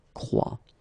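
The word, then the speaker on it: croient: Elle croit. And phonetically here, as we saw, ent is not pronounced, so you get the sound croit.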